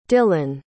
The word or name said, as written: dillan